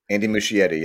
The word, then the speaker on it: Muschietti: Andy and Barbara Muschietti. Am I saying that right? I always get nervous and I say it wrong. How do you say their name? Please help me. – Andy Muschietti, yeah.